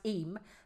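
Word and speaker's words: Eyam: Well, Liz Danziger has messaged and said, I pronounced it Iam. She said it's pronounced Eam.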